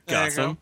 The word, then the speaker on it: Gossam: Gossam rhymes with awesome.